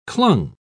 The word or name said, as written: clung